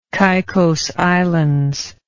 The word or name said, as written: caicos islands